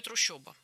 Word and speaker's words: трущоба: Ну, нам, наверное, не так-то принципиально, как то, что там действительно пишется «о». Как и в слове «трущоба». Хотя слышится очень ёшно, по-моему.